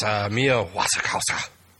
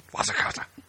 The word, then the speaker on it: Wasikowska: No, it's Mia Wasikowska. – You have to say it like that. – Wasikowska.